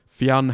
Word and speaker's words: Fionn: Aussie stadium announcers, Caribbean commentators, and the whole shebang in between have been saying his name as two syllables, and I'd love to hear one person just say Fionn properly.